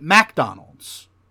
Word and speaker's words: McDonald's: So they don't pronounce McDonald's. They pronounce it MacDonald's.